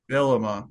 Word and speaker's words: Bielema: Bielema? Is that how you say it? Uh, Bret Bielema. Bielema, that's it.